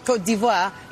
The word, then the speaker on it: Côte d'Ivoire: Côte d'Ivoire.